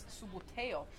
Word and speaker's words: Subbuteo: Now, the sport, for those of you that may know, the classic Italian pronunciation of it is subbuteo, and it's based on that, but the playing equipment for elite players is of a higher standard.